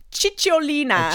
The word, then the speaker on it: Cicciolina: Siocilina? – Cicciolina.